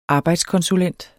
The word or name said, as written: arbejdskonsulent